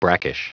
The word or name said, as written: brackish